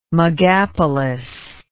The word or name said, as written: megapolis